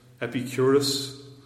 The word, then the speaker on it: Epicurus: Then there's the what's called the trilemma of the Greek philosopher, and I'll probably get the name wrong again, Epicurus. Am I right? Epicurus? Yes. And his trilemma is summarized by the 18th century Scotsman David Hume this way.